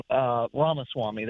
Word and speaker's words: Ramaswamy: I call him Swami, as y'all were just saying, and the last caller there that mentioned that Vivek, however you say his name, Swami, Ramaswamy, that's it.